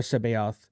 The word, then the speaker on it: C'baoth: Now I say Jor Sabaoth because I, like I said, I used to butcher this name, but we got Joruus C'baoth, an insane dark Jedi who played an essential role in the Thrawn campaign.